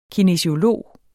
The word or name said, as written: kinesiolog